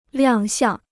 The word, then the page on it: 亮相: liàng xiàng